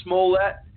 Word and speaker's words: Smollett: Smollett, the fake hate crime perpetrator in Chicago who's now facing felony charges.